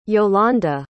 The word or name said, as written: yolande